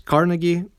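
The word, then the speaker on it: Carnegie: А, я знаю, что авторов на английском э, называют с ударением на «а», то есть «Карнеги», но я не знаю, моему русскоговорящему уху просто это звучит приятнее, да, у Карнеги.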